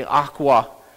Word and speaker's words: Auca: He was a missionary that had gone to the, it's spelled AUCA and pronounced apparently Aqua Indians.